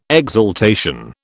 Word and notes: exaltation: British English

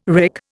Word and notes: wrick: Indian

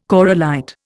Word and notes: corallite: Indian

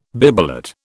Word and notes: bibelot: British English

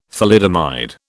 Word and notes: thalidomide: British English

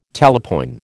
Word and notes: talapoin: American English

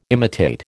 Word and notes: imitate: American English

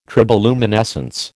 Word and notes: triboluminescence: American English

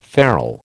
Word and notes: ferrel: American English

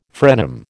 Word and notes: frenum: American English

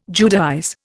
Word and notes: Judaize: Indian

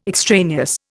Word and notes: extraneous: Indian

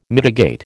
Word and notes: mitigate: American English

